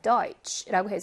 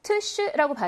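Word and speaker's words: Deutsch: Deutsch라고 해서 츠 발음이에요. 트쉬라고 발음하지 않아요.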